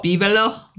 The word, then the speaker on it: bibelot: The word of the day is bibalo. – What? – Bibalo. – Bibalo? – Bibalo. – Bibalo. – Bibalo. It's spelled B-I-B-E-L-O-T. It's a French word.